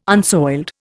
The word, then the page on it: unsoiled: Indian